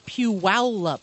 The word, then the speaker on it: Puyallup: Well, I received an email from alert listener Matt, who subject, how to pronounce, you know, that town. – Right. – And he says, it's Puyallup.